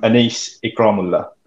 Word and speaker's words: Anees Ikramulla: I mean, you say it how it's written, Rob. We're going to get this right one day. And I can't believe it. You butchered my first name as well. I'm Anees Ikramulla.